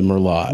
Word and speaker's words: Merlot: It's that silent T at the end. I don't trust it. – The Merlot.